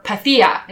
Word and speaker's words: Pythia: Or Pythia, if I was going to say it properly, owing to Assassin's Creed Odyssey.